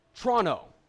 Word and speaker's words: Toronto: By the way, if you want to say Toronto like a Canadian, it's Toronto. That's how you pronounce it. T-R-O-N-N-O.